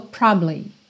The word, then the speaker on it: probably: Essa palavra é muito interessante, porque probably quase sempre soa probably, probably, probably. É como se você passasse tão rápido, mas tão rápido por essa sílaba do meio que ninguém nem escuta.